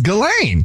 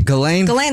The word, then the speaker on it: Ghislaine: I think it's Ghislaine. – Ghislaine. – The S is silent.